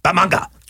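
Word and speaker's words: Bat-Manga: Well, but the really, really cool thing was the book I eventually did on Batman in Japan, which, by the way, the correct pronunciation is Bamanga.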